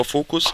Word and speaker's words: Voquz: Ich hatte ja gerade schon gesagt, Sie haben ein solches gefunden, nämlich Focus Labs, geschrieben V-O-Q-U-Z, also eine Variante von Focus, spricht man aber Focus ein Softwarehersteller,